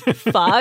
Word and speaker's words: pho: Especially ones that, you know, imply that pho rhymes with show. Or that it's yes. – pho. Yeah. – Oh, yeah. That is my biggest pet peeve. It's pho. It's not pho.